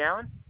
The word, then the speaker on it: Allen: Yep, it's Jesse Allen.